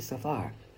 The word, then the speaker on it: Cepher: You know, they spell it C-E-P-H-E-R, but I pronounce it Sefer.